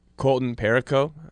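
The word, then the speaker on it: Colton Parayko: And I think, you know, Colton Perico, I believe that's how you pronounce his name.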